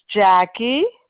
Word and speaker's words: Jackie: My name is Jackie, J-A-C-K-I-E. My last name is G-E-O-R-G-O-P-O-U-L-O-S.